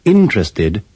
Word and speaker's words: interested: Don't pronounce the second E in interest, interested, and interesting.